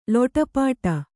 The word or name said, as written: ಲೊಟಪಟ